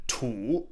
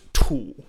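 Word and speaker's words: Tu (吐): Next, from Allison Kerrigan on Tu or Tu in context. This is the character that either means spit on the ground when it's pronounced Tu, third tone, or it means to vomit if it's tu, right?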